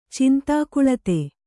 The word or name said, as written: ಚಿಂತಾಕುಳತೆ